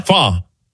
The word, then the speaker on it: pho: Fa? – Fa. – Oh. – Yeah, P-H-O.